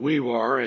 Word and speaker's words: Weewaa: in Weewar, as he says it's pronounced, although it's spelled W-E-E-W-A-A.